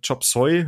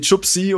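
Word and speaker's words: Chop Suey: Ähm, aber hat es auch im komplett falsch ausgesprochen, dass ich das einfach nicht verstanden habe, was die von mir will. irgendwie, irgendwie Chupsi oder so hat sie gesagt.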